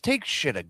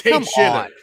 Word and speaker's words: Takeshita: Can we just pronounce it right, please? It's Takeshita. Get it right, guys. Takeshita.